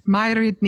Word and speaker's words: Mairead: Her name is Gaelic, and it's got 100 letters in it, but it's pronounced Mairid Ni Hono.